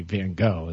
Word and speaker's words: Van Gogh: I was like, it's true that you do pronounce it that way. Like, I heard somebody actually pronounce it the way – I guess the way you're supposed to the Dutch way to say Van Gogh is that.